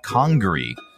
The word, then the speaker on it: Congaree: Congaree.